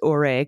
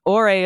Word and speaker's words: Ouray: Several listeners wanted us to know that we mispronounced Ouray, Colorado. Lauren L. wrote in specifically, the town where you, where some numbnut stole newspapers in the misguided hope that it would stop the actual news. Ouray is pronounced Ouray, and it's an incredibly charming little town dubbed Little Switzerland.